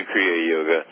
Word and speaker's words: Kriya Yoga: You also have something called, I hope I'm saying it right, Kriya Yoga? – Yes, yes. We also have initiations into Kriya Yoga.